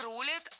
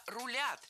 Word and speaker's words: рулят: Не рулят, а рулят. Переносим ударение за корень и получаем правильный вариант.